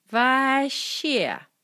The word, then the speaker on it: Вообще: Вообще, pronounced Vaще.